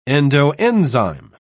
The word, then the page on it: endoenzyme: British English